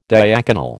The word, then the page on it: diaconal: American English